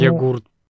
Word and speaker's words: йогурт: Йогурт. – Боже упаси. Кто? Йогурт. У нас в слове йогурт можно теперь ставить ударение где хочешь.